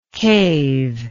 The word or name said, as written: cavelike